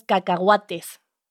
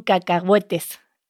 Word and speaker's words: cacahuates: Pero me ha gustado como, no sé si, ¿cómo has pronunciado cacahuete? – Ah, es que nosotros decimos cacahuates. – Cacahuates. – Y ustedes dicen cacahuetes.